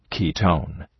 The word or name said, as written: ketone